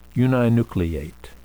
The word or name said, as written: uninucleate